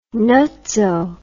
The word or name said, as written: nutso